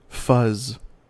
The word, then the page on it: fuzz: American English